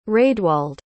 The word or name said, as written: raedwald